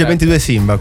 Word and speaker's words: 22 Simba: Si legge 22 Simba, quindi? – Sì. – 22! Mi chiedevo come leggerlo.